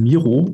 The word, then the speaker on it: Miro: Tatsächlich wird es in Miro ausgesprochen. Also wir sind da nach dem Juan Miro benannt, dem spanischen Künstler aus dem Surrealismus, der ja tatsächlich auch sich da äh, ausgetobt hat und der Kreativität keine Grenzen äh, gelassen hat.